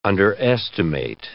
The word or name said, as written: underestimate